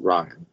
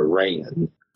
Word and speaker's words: Rian: But Rian Johnson, which is funny, like, I think if you're actually Irish, I don't think you pronounce it Ryan. It's more of a Rayan. – Huh. – But I might be wrong.